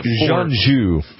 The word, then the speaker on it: Jeonju: Jeonju, I believe is how it's pronounced.